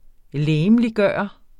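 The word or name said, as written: LEGEMLIGGØRE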